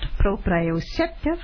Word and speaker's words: proprioceptive: and I hope I pronounced this correctly, but it's called proprioceptive. That's it. Muscles and joints.